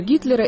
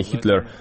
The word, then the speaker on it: Гитлер: И метафорическое отражение того, что происходит с нашей памятью, потому что там говорят, что нужно произносить Гитлер, а не Хитлер.